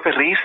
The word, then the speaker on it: Ferriz: Pedro Ferriz and Salvador Frejedo. Can you, and I probably mispronounced those names terribly, but... – No, that's quite all right. That's Pedro Feliz and Salvador Frejedo.